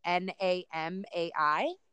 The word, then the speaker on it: Namai: which is spelled N A M A I.